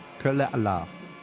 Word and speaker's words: Kölle Alaaf: And how do I say that again? – Kölle Alaaf.